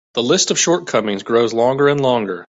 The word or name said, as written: shortcomings